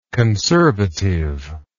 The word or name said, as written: conservativeness